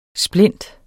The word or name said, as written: SPLINTENS